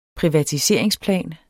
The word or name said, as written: privatiseringsplan